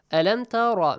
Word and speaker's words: أَلَمْ تَرَ: So, أَلَمْ تَرَ. Make sure Ra is pronounced with a heavy sound.